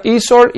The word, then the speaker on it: eSword: Se pronuncia eSword y se escribe eSword con W, como espada electrónica. E de electrónico, guión, espada en inglés, SWORD.